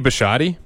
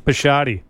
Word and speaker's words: Bisciotti: Okay, Baltimore Ravens that Steve Biscotti, is that how you pronounce that? You're Italian. – Yeah, it's Steve Bisciotti. – Bisciotti.